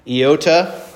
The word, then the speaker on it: Iota: Iota, that's your short I, as in like a pizza, the word pizza in English.